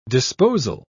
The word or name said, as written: disposal